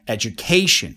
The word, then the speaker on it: education: If you guessed it A, then you're right, because the actual stress is on K, education. You see, I'm saying education. So I'm now emphasizing on education. which means that the stress is on education, on the third syllable.